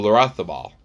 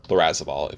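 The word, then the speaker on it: Larrazábal: Your other Spanish play this week could be Pablo Larrazabal. Larrazabal, if you would like to say it like an English person.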